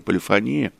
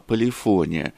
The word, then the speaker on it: полифония: Да, именно с таким ударением музыканты говорят не полифония, а полифония.